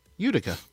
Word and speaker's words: Utica: Now, they're playing as we speak against Hartford, followed up with a game in, I never know how to say this word properly, Utica? – Utica.